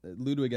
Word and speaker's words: Ludwig: A lot of people pronounce it like that. Ludwig, as in Ludwig van Beethoven.